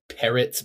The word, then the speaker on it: Peretz: But it's like it's spelled P E R E T Z. Yeah, like Peretz. Yeah, par- but not not P A R R O T S, even though they sound the same.